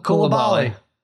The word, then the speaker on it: Coulibaly: How do you say it? Bilal Coulibaly? – Coulibaly.